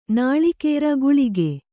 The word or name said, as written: ಗುಳಿಗೆ